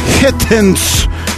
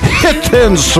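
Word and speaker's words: Heathens: Hittens. Hittens. Вот так произносится эта вещица.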